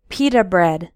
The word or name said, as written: pita